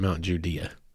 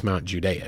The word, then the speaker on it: Mount Judea: It's actually, they, they pronounce it Mount Judea here in Arkansas. Properly, it's Mount Judea because it's actually named after Mount Judea in Israel.